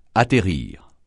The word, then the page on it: atterrir: francais